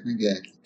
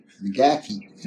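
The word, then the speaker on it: McGaughey: G-A-U-G-H-E-Y. – I heard that M-C, pronounced. McGackie. – I've heard it pronounced McGee, too. – Well, we said McGackie.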